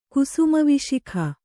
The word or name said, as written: ಕುಸುಮವಿಶಿಖ